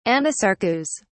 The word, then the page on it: anasarcous: British English